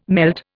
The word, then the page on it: melt: Indian